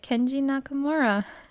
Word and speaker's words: Kenji Nakamura: But yeah, so Kenji Nakamura, I cannot pronounce this guy's name. – You just said it. – I was the director. – You said it, Kenji Nakamura.